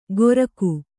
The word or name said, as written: ಗೊರಕು